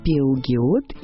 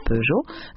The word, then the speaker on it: Peugeot: До сих пор на находятся сограждане, упоминающие в беседе некие Пеугиот и Ренаульт. В то время как правильное произношение намного легче и благозвучнее. Помечаем. Основатель автомобильного производства Peugeot звали Арман Peugeot, а компанию Renault создали братья Луи, Марсель и Фернан Renault.